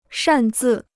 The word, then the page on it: 擅自: shàn zì